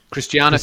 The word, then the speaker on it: Christiana: Got it here with your co-author, Christina. Christiana. How do you pronounce it? – Christiana Figueres.